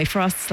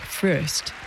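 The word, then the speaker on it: Fröst: By the way, Frost's last name is spelled with two dots over the O and umlaut, so in his native Swedish, it's pronounced more like Frost. But in English, everyone seems to just agree to pronounce it Frost.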